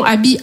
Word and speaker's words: Aby: Non, comme je vous ai dit, Abby, c'est mon prénom. Abby, A-B-Y, trois lettres, deux syllabes.